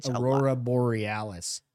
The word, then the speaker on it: Aurora Borealis: Aurora Borealis.